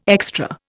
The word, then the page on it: extra: Indian